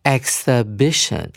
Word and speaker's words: exhibition: 一是念一原本的音 e，然后 x 念成 x，所以我们会念成 exhibition。哦，把那个 x 的那个 s 的音呢跟后面的 i 念成。呃，粘在一起，所以你就会念成 x，然后重音在 b i 这个音节上面，exhibition。